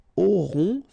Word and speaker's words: auront: then ils auront, final T not pronounced, O-N together, on, auront, okay, and then the link between the two, ils auront, elles auront.